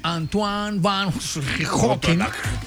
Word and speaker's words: Antoine van Leeuwenhoek: Bueno, mire, un día como hoy de 1683, el científico holandés Antoine van Leeuwenhoek. – ¿Cómo es? Sí. – Antoine van Leeuwenhoek. Es que así se pronuncia. ¿Usted por qué se burla de mí? Estoy pronunciando en holandés.